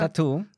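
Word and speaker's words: t.A.T.u.: Vuelve a los escenarios TATU, el dúo ruso. Tattoo. Tattoo. ¿Así se pronuncia? – Tattoo.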